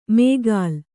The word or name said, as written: ಮೇಗಾಲ್